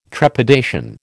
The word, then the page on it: trepidation: American English